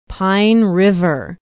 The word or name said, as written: pine river